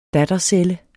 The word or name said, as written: dattercelle